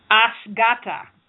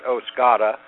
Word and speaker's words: OSGATA: So why don't you start us out with telling us a little bit about Asgata. Did I pronounce that correctly? – Well, it depends what corner of the country you're from. Many of us call it Osgata.